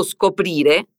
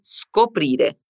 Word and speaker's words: scoprire: Preste muita atenção à pronúncia de scoprire. A S inicial é muda. Repete comigo. Scoprire.